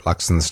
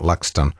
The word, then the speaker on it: Luxon: Somebody's texted saying, why can't people get Luxon's name right? It's Luxon, not Luxton.